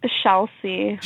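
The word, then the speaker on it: Shalsy: How do you how do you pronounce that? – shall see. – Shall like, see. Like shall see. – Shall like we see.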